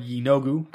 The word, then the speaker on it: Yeenoghu: Um, one point long ago, the demon lord Yeenoghu comes to the material plane, and I really hope I'm saying that right. – We'll say um, you are.